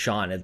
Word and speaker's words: Sean: Is Sean pronounced Sean? They're like, well, there's no H in there.